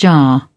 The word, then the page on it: jar: British English